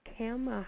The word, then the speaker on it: Khama: Welcome, Miss Khama.